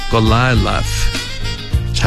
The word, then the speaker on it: 글릴롯: 먼저 엔세메스부터 엔시메쉬 엔시메쉬 이렇게 발음하시고 다음에 글릴롯은 골라일라스 잘 듣고 따라해보세요. 골라일라스 이렇게 연습해 주시기 바랍니다.